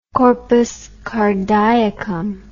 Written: corpus cardiacum

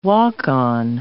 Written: walk-on

